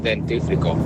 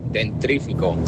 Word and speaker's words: dentífrico: Y la palabra, me acabo de entrar ahora que la decía mal, gracias a vosotros, es dentífrico. – ¿Ah? – Y decía dentrífico.